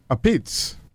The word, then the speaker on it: apizza: So I enjoyed this interview to hear you and Brian go back and forth on the pronunciation of the true Italian pronunciation of pizza. – Right. – A pizza. – Well, that's actually the New Haven pronunciation.